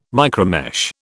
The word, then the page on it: micromesh: British English